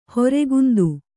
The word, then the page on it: ಹೊರೆಗುಂದು: kannada; horegundu